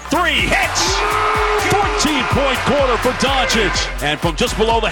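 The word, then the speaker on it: Dončić: By the way, his last name is correctly pronounced Doncic.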